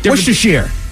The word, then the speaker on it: Worcestershire: This is a certain kind of sauce that people like to put on different Worcestershire. – Correct. Worcestershire sauce, you absolutely said it correctly.